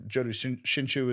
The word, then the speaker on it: Jodo Shinshu: Are there, I know the Jodo Shinshu is the, if I'm even saying that right.